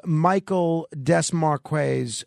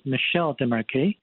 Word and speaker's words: Michel Desmarquet: Yes, Michael Desmarques is a French-Australian, and in French, his name is pronounced as Michel Desmarques.